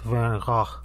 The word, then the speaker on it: Van Gogh: Once my oma told me that in Dutch, Van Gogh is actually pronounced Van Gogh. It's kind of more guttural and throaty sounding than what we usually say.